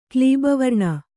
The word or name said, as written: ಕ್ಲೀಬವರ್ಣ